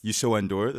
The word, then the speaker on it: Youssou N'Dour: Youssou N'Dour, that's how you pronounce it? – Yeah, okay.